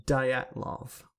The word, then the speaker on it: Dyatlov: Dyatlov. That's how you pronounce it.